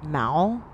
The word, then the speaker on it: Moel: Did you know that Darby's last name is pronounced Mal? – I did not realize that. – Oh, like towel.